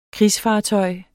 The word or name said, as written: krigsfartøj